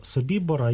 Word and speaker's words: Собібор: У випадку Собібора я наголошую, що я вживаю такий наголос через те, що цей табір розташовувався на території Польщі, і поряд із ним знаходиться польське село Собібор. яке за правилами польської мови має наголос саме на голосний «і».